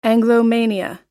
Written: anglomania

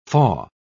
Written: faugh

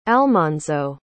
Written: almonzo

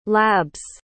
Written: lab's

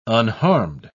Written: unharmed

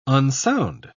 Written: unsound

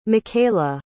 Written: micayla

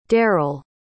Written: daryl

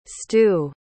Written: stu

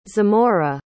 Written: Zamora

